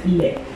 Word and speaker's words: laid: Le prochain mot, ce n'est pas un mot qu'on utilise juste au Québec, bien au contraire, c'est l'adjectif laid. Laid, laid. Sauf que ce qui est particulier au Québec, c'est qu'on prononce comme un T.